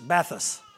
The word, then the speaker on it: bathos: I said it with an accent, but it's bathos. That'll help you spell it, bathos.